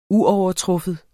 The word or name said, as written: uovertruffen